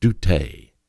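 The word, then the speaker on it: doute (δεῦτε): C-O-M-E. It's an imperative mood of the original Greek word doute. It's spelled D-E-U-T-E, doute.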